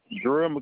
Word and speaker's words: Jarrell: Jarrell McLaren, J-A-R-R-E-L-L, McLaren, M-C-C-L-A-R-E-N.